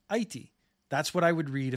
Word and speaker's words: Aichi: It is going to be shifted to the Hepburn rules, which would spell it as A-I-C-H-I, Aichi, which is more phonetically similar to what the Japanese say.